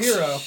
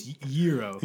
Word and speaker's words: Jiro: My father was Jiro Gamwitch. – Not Hero. – Not Hero. – It's a soft G. It's Jiro.